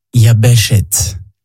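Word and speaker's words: Yabeshet (יבשת): Il se dit en hébreu « Yabeshet ». Yabeshet s'écrit de la manière suivante, Yod, Bet, Shin, Tav, Yabeshet.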